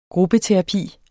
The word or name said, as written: gruppeterapi